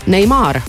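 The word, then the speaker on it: Neymar: Ronaldo saab kolmkümmend üheksa, Portugali jalgpallur ja Neimar no..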